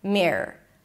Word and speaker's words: Mirror: Black me, relaxed I, and then don't get all confused with the R and the O and the R. Just make it like a long R. Mirror. Mirror. I kid you not. Just pronounce it like one long R.